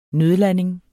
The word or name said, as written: nødlanding